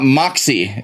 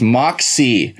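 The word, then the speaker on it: Moxee: It's not Moxie. It's Moxie. Think of it as two syllables.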